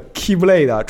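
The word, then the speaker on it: key blade (χ-blade): 发挥了全力的菲恩托斯，在与瓦尼塔斯这么激烈战斗之中啊，就如传说所现，那个 key key 刃就是那个 i 写作 x 读作 key 的 key blade 出现在了所有人的面前。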